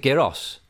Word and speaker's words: gyros: Gyros? – Is it the Greek rap? – Yeah, G-Y-R-O-S. Yeah, it's Greek. – So I'll just say it, Gyros.